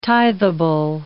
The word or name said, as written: tithable